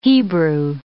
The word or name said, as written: Hebrew